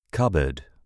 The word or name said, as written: cupboard